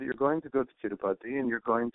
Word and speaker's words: Tirupati: Tirupati. Tirupati.